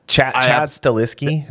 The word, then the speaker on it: Chad Stahelski: Yeah, Chad Stahelski, is that how you say his name? – Yeah.